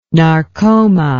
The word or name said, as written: narcoma